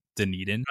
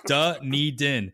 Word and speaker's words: Dunedin: And yes, that is how you say Dunedin. – I didn't know. – Yeah. Dunedin.